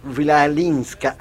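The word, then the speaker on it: Chwalińska: en Maja Vilalinska, así. – Lo estaba practicando. – Lo estaba practicando, porque se escribe Chwalinska.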